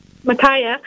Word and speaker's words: Micaiah: I'm not sure if I'm pronouncing the prophet correctly. It's Micah or Micaiah? – Micaiah. Yeah.